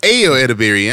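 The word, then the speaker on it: Ayo Edebiri: Edo Atterbury. I'm probably pronouncing that shit way the fuck wrong. – I think it's Ayo Atterbury.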